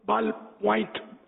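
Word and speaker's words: ballpoint: اور قلم ہو یہ واٹر پروف، یہ بوائل پوائنٹ، بول بال پوائنٹ، اس کو بول پین ہم بولتے ہیں، اس کا اصل تلفظ ہے بال پوائنٹ، غالباً یہ اس کا اصل ہے،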